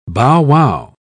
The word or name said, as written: bowwow